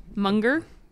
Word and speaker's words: Munger: How do you pronounce his last name? Munger? – Yeah.